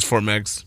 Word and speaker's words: Fort Meigs: Did I say that right? – It is Fort Meigs.